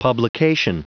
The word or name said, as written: publication